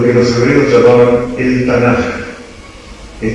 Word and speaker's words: Tanaj: La Kaf final se pronuncia como Jota, entonces uniendo las consonantes se lee Tanaj.